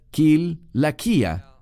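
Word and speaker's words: Kil Laquía (Κιλικία): Kil Laquía, Sé que muchos lo pronuncian con un sonido de S, porque tiene una C, pero en griego se pronuncia Kil, kia Así que él era de allí.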